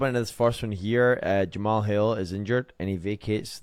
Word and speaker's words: Jamahal: Jamahal Hill.